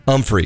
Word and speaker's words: Humphrey: So his name is actually does start with an H, but it's pronounced Umphrey, because he's from he's from Mississippi.